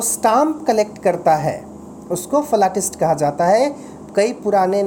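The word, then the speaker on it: philatelist: फलाटिस्ट फलाटिस्ट exact spelling जो है वो है P H I L A T E L I S T लेकिन पढ़ेंगे क्या फलाटिस्ट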